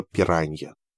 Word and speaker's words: пиранья: В голову вдруг приходит, что мне уже давно не терпится показать в разговоре правильное произношение слова «пиранья». Пир-ань-я. Поразительно, как много людей в мире не умеют говорить по-португальски. Еще несколько слов, которые я рассчитываю когда-нибудь правильно произнести в разговорах с людьми.